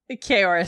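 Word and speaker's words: Ceoris: So the Shamazé, they start laying siege, how'd you pronounce it? Corius? – Kaoris. – Kaoris. – Yeah.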